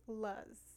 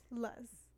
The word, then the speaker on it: Luz: I always had issues with like people in like school, especially like Luce? Saying my name. My name was not Luce. My name was Luz. – Luz? – That's literally how everybody would pronounce my name. – Luz? – Luz.